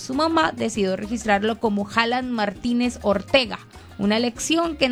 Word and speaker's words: Haaland: En noruego esa doble A es una doble O. Es Haaland. Haaland. Haaland en la pronunciación correcta, pero nosotros los latinos y la gente en todo el mundo sigue diciendo Haaland.